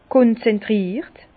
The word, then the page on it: konzentriert: Bas-Rhin; Herrlisheim